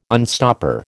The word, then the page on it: unstopper: American English